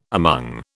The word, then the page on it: among: British English